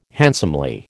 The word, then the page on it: handsomely: American English